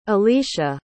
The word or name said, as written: alesha